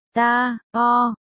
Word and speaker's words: Tarot: Tarot, phát âm tiếng Pháp, ta, o,